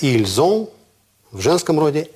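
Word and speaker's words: ils ont: То есть эти буквы «С» начинают читаться, потому что следующая буква – гласная. – Как «С» читается? «З», как «З». «Ильзон», «эльзон». Еще раз.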